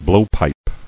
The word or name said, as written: Blowpipe